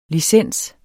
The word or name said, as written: LICENSERNES